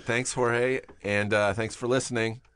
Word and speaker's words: Jorge: Instagram, he's got a very great Instagram, and it's pronounced Jorge. How do you spell the phonetical? – H-O-R-H-A-Y, yeah. – H-O-R-H-A-I. – H-A-Y. So it's, the words pronounced H-O-R-H-A-Y.